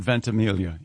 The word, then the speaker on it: Ventimiglia: Is it Ventimiglia? – Does he go with the soft G or does he do the Mike Birbiglia thing? – I have heard the soft G. – I've heard Ventimiglia, yes.